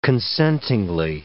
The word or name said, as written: consentingly